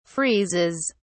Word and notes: freezes: British English

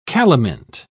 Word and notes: calamint: British English

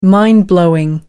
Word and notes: mind blowing: American English

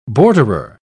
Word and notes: borderer: British English

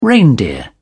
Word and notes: reindeer: British English

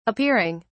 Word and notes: appearing: British English

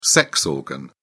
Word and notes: sex organ: British English

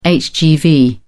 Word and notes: hgv: British English

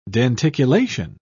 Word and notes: denticulation: British English

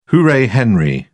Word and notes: hooray henry: British English

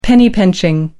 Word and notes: penny-pinching: American English

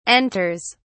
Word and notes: enters: British English

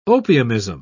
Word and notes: opiumism: British English

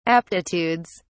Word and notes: aptitudes: British English

